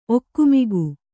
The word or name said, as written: ಒಗುಮಿಗು